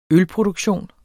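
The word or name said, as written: ØLPRODUKTION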